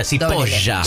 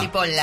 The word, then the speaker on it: Cipolla: Cipolla se escribe con C y con... con Elie, o sea, Cipolla. – Cipolla, ¿no? – Cipolla.